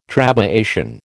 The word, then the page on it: trabeation: American English